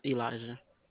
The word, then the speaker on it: Elijah: Elijah. – Spell that, please. – E-L-I-J-A-H.